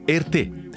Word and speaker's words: Erté: Uno dei maggiori disegnatori di costumi dei Music Hall è il russo Romain de Tirtov, in arte Erté. come la pronuncia francese delle sue iniziali.